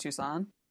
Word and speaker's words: Tucson: he's probably just being a normal dude somewhere in Tuscan, Arizona, or something. Um, it says, but yeah, so Who's Tucson? – It's really? pronounced Tucson.